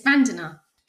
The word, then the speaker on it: Vandana: Yes, it's Vandana.